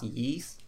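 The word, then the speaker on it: Ys: I believe it's pronounced Yees, but it's spelled Y-S. – Yeah, Y's Yees, yeah.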